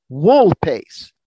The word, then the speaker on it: vulpes: And the last one that we're going to do for today, wool pace. Wool pace. – Wool pace. – Means anyone know? – Spell it. – V-U-L-P-E-S.